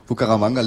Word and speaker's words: Bucaramanga: Kommt an aus Bucaramanga. – Hast du richtig ausgesprochen. – Danke. – Fast. – Also, Bucaramanga liegt in der Mitte von riesigen Ananasplantagen.